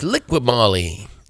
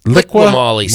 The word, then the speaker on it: Liqui Moly: Is it Liqui Moly Junior Cup? The Liqui? – That's Liqui Moly. Liqui Moly, Scott.